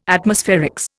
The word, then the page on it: Atmospherics: Indian